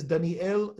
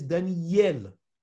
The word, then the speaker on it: Daniel: Everybody who says Daniel is mistaken. The name is Daniel with the Dagesh and the Yod. That is how it's supposed to be pronounced.